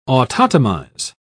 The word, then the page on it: autotomize: British English